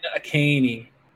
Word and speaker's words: Achane: How are they saying it? – Devin Achane. – Achane? There's an H in there. Why would you assume it's Achane? – Because they thought it was.